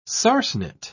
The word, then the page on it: sarsenet: British English